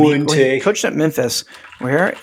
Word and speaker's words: Fuente: And not just isn't it Fuente? – It is. Fuente. – Don't you pronounce he pronounces it weird. Like I think it really is Fuente.